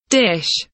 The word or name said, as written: dish